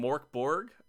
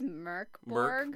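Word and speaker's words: Mörk Borg: I think it's Merkborg. – Merkborg. Yeah, because it's the umlaut over the O, right? You explained to me that it's an R sound.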